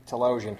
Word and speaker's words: Talosian: Yeah, I think in the previous recording we also did mention how, you know, the theologian being able... – Theologian? – Or Talosian. How do you say it? – Talosian.